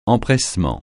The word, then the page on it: empressement: francais